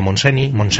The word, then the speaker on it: Montseny: o como Federica Montseny, que siempre mi compañera catalana me decía que españolito eres, que no sabes que la N y la Y en catalán se pronuncian Ñ.